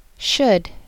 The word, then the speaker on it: should: Should. Should. You should not pronounce the L when you say the word should.